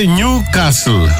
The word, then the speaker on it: Newcastle: Το Νιου Κάστλ, ελληνιστή νέο καστρό, κατ' επέκταση και η ομάδα. Προφέρεται Νιου Κάστλ. – Δεν έχει τάφ, έχει δίκιο. – Ναι, έχει δίκιο. Νιου Κάστλ. Άνευ του.